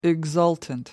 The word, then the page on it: exultant: American English